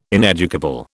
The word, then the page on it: ineducable: British English